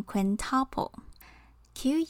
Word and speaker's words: quintuple: 五倍则是 quintuple，quintuple。有些人也会念成 quintuple，Q U I N T U P L E。但其实从三倍开始，我们都可以直接用万用的 time 这个字代替，比较简单。 time 除了当时间以外，也有倍数的意思。所以 three times 就是 triple，four times 就是 quadruple，five times 就是 quintuple，之后就是 six times，seven times 等等。